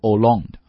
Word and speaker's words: Hollande: François Hollande linked the fight against terrorism, a world free of terror, to that against the global warming, a planet free of catastrophes. 啊，主语是法国的总统 François Hollande 啊，第一个单词读 François 啊，然后后面那个 H 不发音，读 Hollande 啊，这个奥朗德啊。